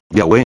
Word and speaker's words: Yahweh: Los traductores, ignorantes de esta tradición judía, no pronunciaron el nombre como Yahweh, estando influenciados por los judíos y su cambio por las vocales de Adonai. Por consiguiente, ellos escribieron Jehová.